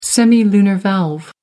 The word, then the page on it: semilunar valve: American English